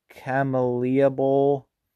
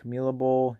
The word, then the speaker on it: Camellia: Buffalo over Marshall in the Camellia Bowl. – Camellia Bowl. – Yeah, I knew I was saying that wrong.